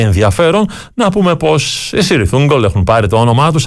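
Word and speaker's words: Cirith Ungol: Είναι εκείνος ο πύργος, εκείνο το πέρασμα, το οποίο από ό,τι λένε οι μελετητές τώρα του Tolkien προφέρεται Kirith Ungol, αλλά το συγκρότημα προτιμά να το λέμε τον δικό του τρόπο.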